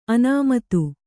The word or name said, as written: ಅನಾಮತ್ತು